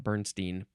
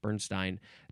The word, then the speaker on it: Bernstein: I think that's how you pronounce, not Bernstein, Bernstein.